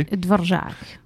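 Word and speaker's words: Dvořák: Dvorak.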